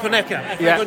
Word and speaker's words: Onyeka: But Anika, I thought, was fantastic. – Who was that? – Anika. – Frank Onyeka.